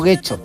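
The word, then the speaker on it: Getxo: No, Guecho, no, Guecho. – Guecho.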